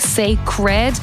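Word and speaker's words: Cécred: She just recently launched a brand new collection of hair products called Sacred. It is spelled C-E-C-R-E-D, obviously Beyonce, Sacred.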